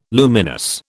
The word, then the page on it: luminous: British English